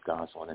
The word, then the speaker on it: Gosselin: And just one last thing, if anybody did want to look at my website, it's on the SEEK website, S-E-A-K. You just look under Emergency Medicine and Dr. Edward Gosselin, and that would be my website. – Gosselin is spelled G-O-S-S-E-L-I-N.